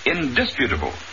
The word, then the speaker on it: indisputable: The word is indisputable, with the accent on the second syllable.